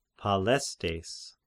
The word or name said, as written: Palestes